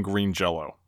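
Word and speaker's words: Green Jellÿ: but the Y had a little umlaut over it. And they claimed that that made the Y sound like a long O. So even though written out it was Green Jelly, they still pronounced their name Green Jello.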